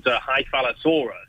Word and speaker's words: Hyphalosaurus: Yeah, so that is a really interesting beastie called, yeah, you're quite right, good pronunciation there, called Hyphalosaurus. And Hyphalosaurus is this,